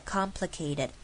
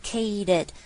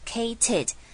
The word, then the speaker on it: complicated: OK, complicated 这个字是非常常见到的字， complicated 记住它的声调是的的的的，一阶阶往下的 complicated。然后有一个小技巧可以告诉你们 ,kaded 这个地方 ,ted, 我们会把它念成像是 ded, 就是 kaded,kaded。那请不要傻傻的直接念 kated, 这样不好听也太刻意了。